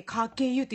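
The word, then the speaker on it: 鹿教湯: が教える湯って書いて掛湯って読む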